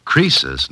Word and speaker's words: Croesus: Crisis was a rich king. – Oh, don't you know, Susie? Croesus, not Crisis, was a rich king.